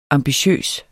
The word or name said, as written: ambitiøs